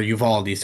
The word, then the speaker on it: Uvalde: Like, even when Bungie will tweet, like, about, like, the Buffalo shooting that happened, or the Oveda, Texas, or Uvalde, sorry, that's how you pronounce it, shooting that happened.